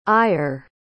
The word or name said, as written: eir